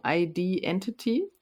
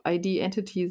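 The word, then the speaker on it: ID.Entity: Auf der 4 haben wir Riverside mit dem Album ID Entity, spricht man es, glaube ich, aus. Das ist so geschrieben, dass man es auch Identity aussprechen könnte.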